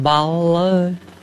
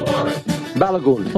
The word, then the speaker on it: Balogun: soccer star Florin Balogun. – There you go. – Yeah. – Sorry, say again. – Balogun.